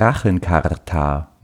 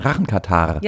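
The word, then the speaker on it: Rachenkatarrh: der Rachenkatar. Nee, Rachen... Entschuldigung, ich habe es falsch ausgesprochen. Rachenkatar.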